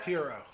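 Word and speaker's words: Herro: Tyler Hero, how do you say that? – Hero.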